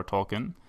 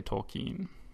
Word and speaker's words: Tolkien: Yeah, I actually corrected my pronunciation in the interview. Like, I pronounced it Tolkien at first, and then afterward, he said Tolkien, and I was like, "Oh, I better pronounce it Tolkien from now on."